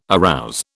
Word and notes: arouse: British English

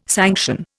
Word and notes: sanction: Indian

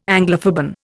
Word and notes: Anglophoben: Indian

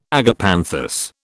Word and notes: agapanthus: British English

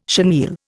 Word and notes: chenille: Indian

